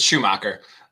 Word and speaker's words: Schumacher: I mean, it's very German, so if you want to... – It is. I looked up the pronunciation. It was like Schumacher. – Yeah. – How do you say 75% German. It's Schumacher.